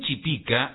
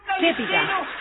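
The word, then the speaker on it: chépica: Si bien Chipica está aceptada y registrada en varios países de Sudamérica como Argentina y Chile, según la RAE, el término correcto es Chepica. Con acento en la E, es una palabra esdrújula. – Ah, Chepica, entonces.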